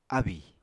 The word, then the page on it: ábì: apatani